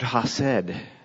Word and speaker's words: chesed: Now, if I were a Hebrew, speaking Hebrew firsthand, I wouldn't pronounce it the way I just did. There is a back of the throat roll to that CH sound, and I can't do it justice, but the word chesed is a word that we don't have an English equivalent for.